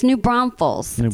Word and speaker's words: New Braunfels: I know, it's New Braunfels. So thank you for saying it correctly.